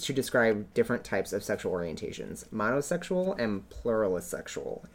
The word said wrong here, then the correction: plurisexual: Oh. Pluralsexual. – Plurisexual. – Plurisexual. There you go.